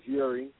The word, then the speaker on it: Uri: Or you can look me up on there as Uri, which is U-R-I.